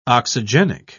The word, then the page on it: oxygenic: British English